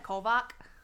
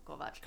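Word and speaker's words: Kovač: I love how she's like, how do you pronounce that? Kovac? He's like, no, Kovac.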